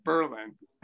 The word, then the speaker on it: Berlin: And then during the Second World War, our big contribution to the war effort was to officially change the pronunciation of the name of the town to Berlin and take Berlin. The accent off the second syllable so that no one would accidentally think we were allied with the German forces during World War II.